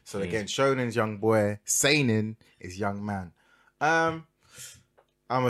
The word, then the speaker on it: Seinen: Seinen.